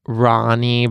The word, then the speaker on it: Rani: Oh, Kate Hudson, who had like Ronnie, but then she had to like explain on her Instagram account how you pronounce it. It's spelled like R-A-N-I.